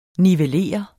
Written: nivellere

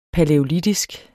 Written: palæolitisk

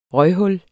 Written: røghul

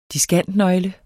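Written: DISKANTNØGLE

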